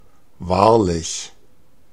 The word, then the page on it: hyvin: /ˈhyvin/